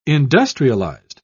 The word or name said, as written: industrialized